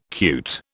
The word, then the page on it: cute: British English